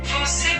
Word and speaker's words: você: Você merece até a lua. Você ah, merece até. – Ya. Gua tadi ngomongnya poce poce.